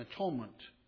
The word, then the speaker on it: atonement: How did you say that? At one ment. Thank you.